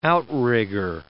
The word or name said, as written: outrigger